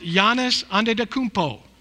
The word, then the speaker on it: Giannis Antetokounmpo: Um, his name is pronounced Giannis Antetokounmpo.